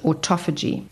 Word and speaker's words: autophagy: Another area is it increases something in the body called autophagy. Autophagy, I'm going to spell it, A-U-T-O-P-H-A-G-Y.